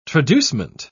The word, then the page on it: traducement: British English